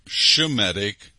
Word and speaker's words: Semitic: Semitic blood in them. And that word used to be pronounced Shemitic, as in Shem.